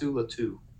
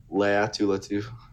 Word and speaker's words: Laiatu Latu: How do you say it correctly? First and last Laiatu Latu. – Laiatu Latu. Okay.